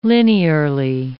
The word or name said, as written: linearly